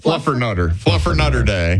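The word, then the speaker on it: Fluffernutter: Happy National Flutter Mutter Day. – Excuse you? – Fluffer Mutter Day. – Nope. Try again. – Not for you? – You're still saying it wrong. – Oh, Fluffer Nutter Day.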